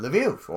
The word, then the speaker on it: Lviv: helping the Nazis kill Jews in in Lviv or or Lvov if you're using um, a, uh, a Russian translation Russian uh, uh pronunciation of that.